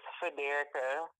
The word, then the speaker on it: Federica: And my name is Federica from America because my name rhymes with America and I just so happen to be from America, so it's factual.